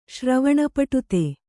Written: ಶ್ರವಣಪಟುತೆ

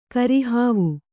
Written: ಕರಿಹಾವು

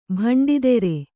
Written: ಭಂಡಿದೆರೆ

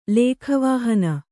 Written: ಲೇಖವಾಹನ

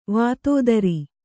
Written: ವಿಧುಧರ

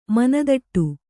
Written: ಮನದಟ್ಟು

